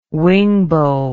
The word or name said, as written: wingbow